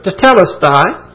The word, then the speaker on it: tetelestai: You know that word is tetelestai. Some pronounce it tetelestai.